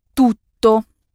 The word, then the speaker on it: tutto: Tu se dit tutto. T-U-D-T-O. Tutto. Avec deux T qu'il faut prononcer bien distinctement.